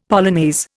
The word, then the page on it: polonaise: Indian